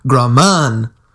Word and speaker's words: Graman: I said, Steven Graman rhymes with ramen noodles.